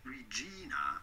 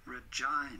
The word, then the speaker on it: Regina: Do not say Regina, but rather Regina.